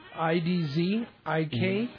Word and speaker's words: Idzikowski: IDZIKOWSKI. Idawaski, I think? – Say it again. – IDZIKOWSKI.